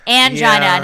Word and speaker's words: angina: Unfortunately, the correct way to pronounce this word rhymes with vagina. Angina, not angina, not angina, but angina."